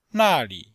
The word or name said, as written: na4li5